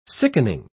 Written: sickening

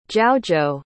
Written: jiaozhou

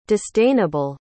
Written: disdainable

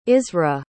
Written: isra

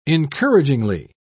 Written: encouragingly

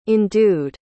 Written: endued

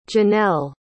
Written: genelle

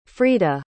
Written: freida

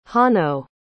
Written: hanno

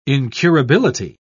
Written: incurability

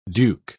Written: duke

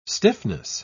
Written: stiffness

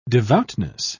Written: devoutness

